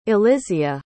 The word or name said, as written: elysia